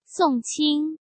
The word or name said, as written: Song Qing